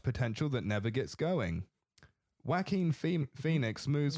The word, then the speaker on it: Joaquin: The J is silent. Like, think Juan. That's the way I remember it. – Yeah. – Like, Juan, like Joaquin.